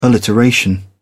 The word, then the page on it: alliteration: British English